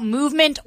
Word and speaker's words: MVMT: Hey, DC Movie News fans, here to tell you guys about Movement Watches. I wear my Movement Watch every single day. That is spelled MVMT, but pronounced Movement.